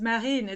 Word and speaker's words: Marine: And marine is marine. It's French. So funny enough, I'm also a marine biologist.